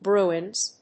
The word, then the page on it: bruins: ˈbruɪnz, ˈbru:ɪnz